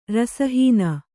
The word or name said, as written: ರಸಹೀನ